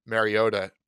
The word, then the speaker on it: Mariota: I don't know if you noticed I paused just so I could say Mariota, because I was going to say it correctly.